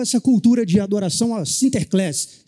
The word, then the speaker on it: Sinterklaas: encurtaram e a pronúncia holandesa ficou Sinterklaas. Sinterklaas. É o São Nicolau abreviado e na pronúncia holandesa.